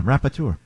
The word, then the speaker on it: rapporteur: UN Special Rapporteur. – How do you spell it? – Rapport. – Hold on. For National Dyslexic Week, go ahead. – R-A-P-P-O-R-T-E-U-R.